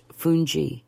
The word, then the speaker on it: fungi: Fungi, pronounced fungi, is a humble yet essential cornmeal-based side, closely related to polenta, but steamed and stirred to a firm, elastic texture.